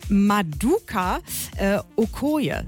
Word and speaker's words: Maduka Okoye: Und jetzt kommt's ja, um den nigerianischen Torwart Maduka Okoye, so wird er richtig ausgesprochen.